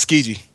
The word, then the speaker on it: Tsukiji: Where it's pronounced Tsukiji, but it's spelled T S U. Damn, I know. – Where did I go get that? – Tsuki. – Yeah, it's called Tsukiji.